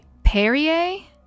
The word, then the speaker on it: Perrier: Perrier? – Yeah.